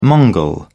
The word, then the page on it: mongol: British English